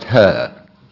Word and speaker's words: He: And funny Mao had a mistress called He. – Yeah. Oh. And funny enough, it's pronounced her.